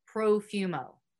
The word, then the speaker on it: Profumo: Side note, if you guys don't know, the Perfumo affair was a major scandal in the 20th century British politics arena. John Porfumo, I think that's how you say it, Porfumo. We're going to go with Profumo.